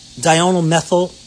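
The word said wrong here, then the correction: diindolylmethane: I'm sorry, I always mispronounce this, even myself after all these years, is diindolmethane. I got to say it slow, not three times fast.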